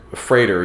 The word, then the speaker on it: Freighter: It's an album by a band called, I guess you pronounce it, Freighter. Freighter, yeah, like freight train.